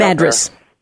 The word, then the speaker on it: Madras: Madras, is that how you pronounce it? – Madras. – Madras, yeah.